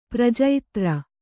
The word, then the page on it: ಪ್ರಜೈತ್ರ: kannada